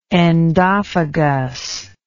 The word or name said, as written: endophagous